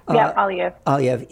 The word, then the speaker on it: Aliyev: The president of Azerbaijan, Aliyev, is that how you say it? – Aliyev.